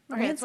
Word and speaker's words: Morantz: Morantz like dance.